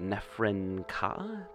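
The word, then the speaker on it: Nephren-Ka: and I wanted to ask you about Nefren Ka.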